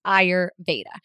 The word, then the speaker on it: Ayurveda: And it's pronounced Ayurveda. The R is not silent, contrary to my previous belief.